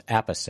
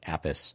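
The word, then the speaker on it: Appice: It has longtime studio drummer Carmen Apice, is how they Wikipedia says his name should be pronounced. Apice. I always thought it was like Apis.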